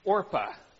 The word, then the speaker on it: Orpah: These women's names are Ruth and, mispronounce it, Orpah. And if I say Oprah today, this is a little known fact, but Oprah Winfrey, her birth name was Orpah.